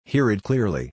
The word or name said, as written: hear it clearly